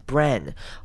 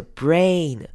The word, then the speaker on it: brain: 接下来 brain 这个字之前 Jonathan 好像有教过，但是很多人还是会念成 brain，或者是发音比较不完整的不完整的长母音 A，那这边我们要念成长母音 A，可以试着讲，假装你在念字母 A，就是要把这个声音念出来，所以会是 brain on the human brain，要注意回去自己听自己的声音是不是有念出 A 这个声音， the human brain。